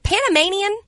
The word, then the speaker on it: Panamanian: And then I saw a video of the is it Panamanian? – Yes, that is how you say it.